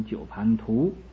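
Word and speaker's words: 九盘荼: 九盘茶鬼，这个九盘茶呀我以前不讲过，本来是读九盘图，但是一般人都不认识这个字，就念成一个茶，那么印也就印成一个茶字，本来是九盘图。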